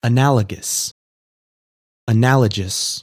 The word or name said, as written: Analogous